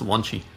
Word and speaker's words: Launchy: We've covered all sorts of stuff that you've done over the years, everything from Dalli to Sidekiq to Launchy, um, Launchy, Lunchy. I'm not sure if I which it's Launchy, Launchy. – I think we pronounce it's spelled Launchy though, right? – Yeah, it is.